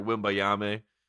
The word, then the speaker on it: Wembanyama: Wembanyama? Did we Google that? Did we get I never got the pronunciation, but you're right. Victor Wembanyama.